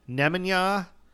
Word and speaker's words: Nemanja: It's the accent's on ne. Nemanja.